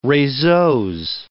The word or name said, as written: reseaux